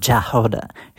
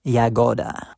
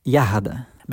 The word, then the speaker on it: Yagoda: This girl name is not Jahoda, her name is Yagoda. More Russian pronounce sounds like Yagoda or Yahada, because it's a Russian word that means berry.